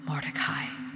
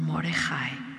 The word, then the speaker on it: Mordecai: But Haman, the son of Hammedatha the Agagite, was in great honor with the king, and he sought to harm Mordecai, or Mordechai, depending on where you're from, how it's pronounced, and his people because of the two eunuchs of the king who were put to death.